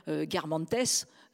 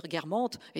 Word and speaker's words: Guermantes: Et hop, oh, vous trahissez que, mon Dieu, vous ne savez pas prononcer correctement, ou vous allez dire Garmantes, au lieu de dire guermantes.